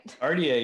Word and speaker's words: Cartier: Did I pronounce that right? – Cartier. Yeah, that's how you say it.